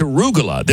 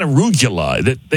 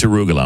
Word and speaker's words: arugula: But man, it's arugula. It's not arugula. He is adding syllables.